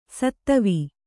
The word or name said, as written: ಷತ್ವ